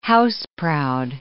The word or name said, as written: house-proud